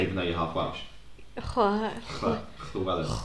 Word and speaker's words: Llewellyn: Llewellyn. Is that how you say it? – Yeah. – Yeah. Because it's like a chl and then a double L.